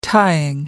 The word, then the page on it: tying: American English